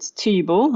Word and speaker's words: Thybo: But there's one called Tybo, which is spelled T-H-Y-B-O, and it's a very umami-rich kind of crystallized cheese.